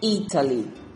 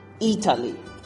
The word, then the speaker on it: Italy: And the number one is a. Address. Number one. Number two is e. Italy. Da la coincidencia que en esos dos primeros, justo la primera sílaba es la primera que se es stress.